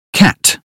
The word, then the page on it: cat: British English